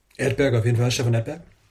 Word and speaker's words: Edberg: Stefan Edberg.